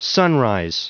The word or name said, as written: sunrise